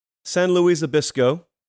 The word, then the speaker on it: San Luis Obispo: They're watching us in, let me get the pronunciation right, San Luis Obispo, California.